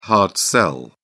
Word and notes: hard sell: British English

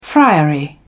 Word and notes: friary: British English

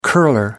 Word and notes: curler: American English

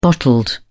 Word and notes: bottled: British English

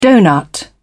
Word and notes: donut: British English

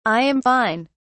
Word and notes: i am fine: British English

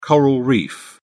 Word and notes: coral reef: British English

